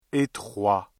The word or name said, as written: étroit